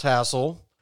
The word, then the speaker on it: Hazel: And so, yes, Hassel Atkins, for some reason spelled Hazel but pronounced Hassel, was born on April 29th, 1937, born in Boone County, West Virginia, where he would live basically his entire life.